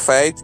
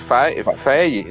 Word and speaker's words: Faye: Yo siempre lo he pronunciado como Fate. Y es Faye, ¿no? Con ah.